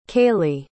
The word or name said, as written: kalie